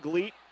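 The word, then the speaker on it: Gliedt: So Grandview with a second chance here to tie with a three as Garrett Gleet brings the ball up the floor. That's G-L-I-E-D-T. The D is silent.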